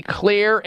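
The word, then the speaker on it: Xlear: And Clear is spelled with an X, X-L-E-A-R.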